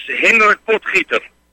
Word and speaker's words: Hendrik Potgieter: Hendrik Potgieter. – Yes, Hendrik Potgieter.